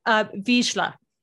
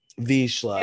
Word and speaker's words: Vizsla: And did I pronounce it right? – Vizsla. – Vizsla. – Very, very Vizsla. Close. Yeah. – I got it. You know, is it Russian? – It's a Hungarian bird dog.